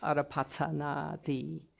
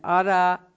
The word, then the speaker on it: Arapachana: which the Tibetans pronounced as Arapachana Di. There's no D in it, and Arapachana, five syllables, are the first five syllables of a Gandharan alphabet.